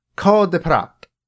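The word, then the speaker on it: Cœur de pirate: Her name is Beatrice Martin, but she, until recently, went by her stage name, Cor de Prat. That's how she pronounces it in the video that I watched. I'm going to trust her to pronounce her own name.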